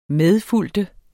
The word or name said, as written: MEDFULGT